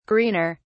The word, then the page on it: greener: British English